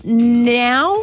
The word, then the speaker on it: Nau: running a few electric blankets now that it's officially winter, etc. – Yeah, I guess. We are looking for Tara Now, I want to pronounce it, N-A-U, Tara Now from Dapto.